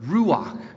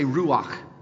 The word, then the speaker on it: ruach: Everybody say ruach. Okay, did you spit on the person in front of you? if you're saying it rightly, there's a little bit of phlegm that just kind of comes out, right? Ruach, that's it, you got it.